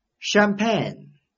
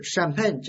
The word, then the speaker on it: champagne: champagne 名词，香槟酒。注意这个香槟啊就是 champagne 这个单词的音译。注意啊这个单词的发音是 ch 发 sh，不要和 champion ch 发 ch 这两个单词先搞混。